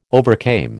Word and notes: overcame: American English

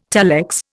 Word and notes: telex: Indian

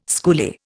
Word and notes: schoolie: Indian

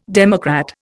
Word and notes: democrat: Indian

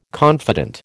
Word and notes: confident: American English

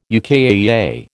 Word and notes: UKAEA: American English

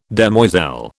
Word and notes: demoiselle: British English

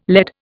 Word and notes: LED: Indian

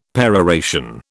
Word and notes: peroration: British English

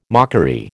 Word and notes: mockery: American English

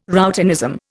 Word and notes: routinism: Indian

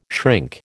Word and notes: shrink: American English

